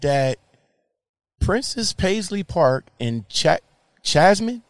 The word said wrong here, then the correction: Chanhassen: and am I pronouncing that right? Chanhassen, Minnesota is now open for public tours.